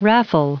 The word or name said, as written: raffle